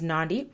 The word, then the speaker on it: Nandi: My name is Nandi, rhymes with Gandhi.